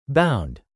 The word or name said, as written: bound